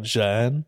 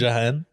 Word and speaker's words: Jeanne: So I committed a sin and went to a thinking machine and said, how the fuck do I pronounce this? And YouTube spit back out Jeanne.